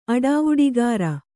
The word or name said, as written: ಅಡಾವುಡಿಗಾರ